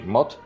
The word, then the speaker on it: mot: Mot significa palabra en francés, mo se pronuncia.